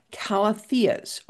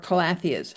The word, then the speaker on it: Calathea: They are all calatheas or calatheas. How do you pronounce that? – I first learned it as Calathea, and then I heard everybody saying Calathea. Take your pick. – Okay, See? – C-A-L-A-T-H-E-A-S.